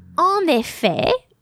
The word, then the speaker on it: en effet: Now, en effet, we don't pronounce the final T.